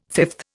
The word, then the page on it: fifth: Indian